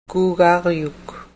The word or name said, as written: kuugaryuk